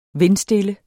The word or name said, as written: vindstille